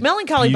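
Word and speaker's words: Mellon Collie: It's Melancholy, by the way, is spelled M-E-L-L-O-N, and then Collie like the dog.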